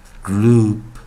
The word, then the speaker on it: group: 那这时候注意一下，grow 加 oop，注意这个 p 要发音喽。 group, group, group。